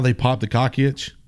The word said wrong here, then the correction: coccyx: The coccyx.